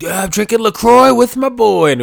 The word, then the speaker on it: LaCroix: And they said, yeah, it's pronounced LaCroix. And then they spelled it like C-R-O-Y is how it's pronounced.